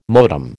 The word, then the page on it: modem: American English